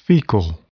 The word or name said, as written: fecal